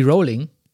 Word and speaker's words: Rowling: Rowling ausspricht. Aha. Sagt man Rowling oder Rowling? Sie selbst sagt, es klingt wie Rowling.